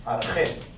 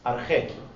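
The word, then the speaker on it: Arjé (ἀρχή): Y la palabra principio en griego se dice así. Arjé. Arjé.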